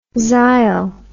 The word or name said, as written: xyl